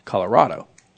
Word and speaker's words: Colorado: Colorado. – That's Colorado. – Colorado. – Okay. Yeah, I mean, R-O-D is clearly Rod, except it's R-A-D in the name of the state.